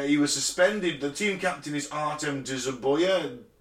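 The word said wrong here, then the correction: Dzyuba: Is that how you say it? – Zuba.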